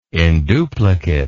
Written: induplicate

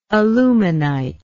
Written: aluminite

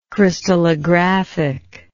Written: crystallographically

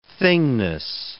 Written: thingness

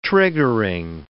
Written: triggering

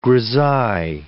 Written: grisaille

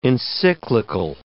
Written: encyclical

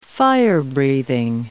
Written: fire-breathing

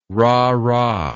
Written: rahrah

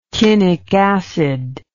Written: chinic acid

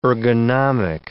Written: ergonomic

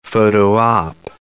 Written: photo op

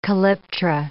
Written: calyptra

